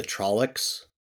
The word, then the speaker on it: Tlaloc: Did you say the Trollocs? – Trollocs. Trollocs. – Okay, thanks. The Trollocs are from the time. – That's what I heard, too. – It's spelled T-L-A-L-O-C. Oh, okay. Trolloc.